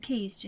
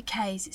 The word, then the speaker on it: Kays: There's an artist called Hayden Keys. Do you know him? – No. – It might be Kays. It's spelled K-A-Y-S. Hayden Kays. – Mm-hmm. – Keys.